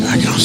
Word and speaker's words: Mackinac: Oh, no, Mackinac Island. – Mackinac Island. – First of all, yeah. – Yeah. It's spelled, why do they spell it Mackinac when it's Mackinac?